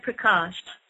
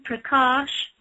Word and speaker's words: Prakasha: And also, as Cindy was saying, you can find me on Facebook, and the name is Anaya Prakash.